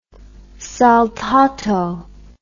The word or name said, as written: saltato